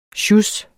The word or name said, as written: sjus